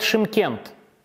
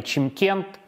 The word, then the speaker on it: Шымкент: Город Шимкент на юге Казахстана. В советское время его называли Чимкент – во время независимости немножко изменили произношение, чтобы это стало более по-казахски.